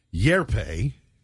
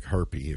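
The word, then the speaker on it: Hjerpe: So in this year's draft alone, there's Cooper Yerpe. – There you go. – It kind of looks like herpy if you don't know how to pronounce okay. – it. It's H. It's H-J-E-R-P-E.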